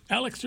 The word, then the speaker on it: Alix: Alex Earl, that's spelled A-L-I-X. Alex Earl has made a career Alex Earle has made a career out of oversharing on the internet.